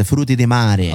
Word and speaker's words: frutti di mare: But that's how you say it, bro. Frutti di mare.